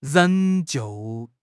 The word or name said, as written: 仍旧